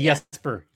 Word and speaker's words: Jesper: Well, it's actually Jesper. Jesper Froden.